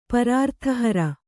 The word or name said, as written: ಪರಾರ್ತಿಹರ